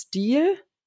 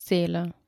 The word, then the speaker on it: Stele: Eine Stele, habe ich das richtig ausgesprochen oder nennt man es Stil? Nee, ich glaube, es heißt Stele.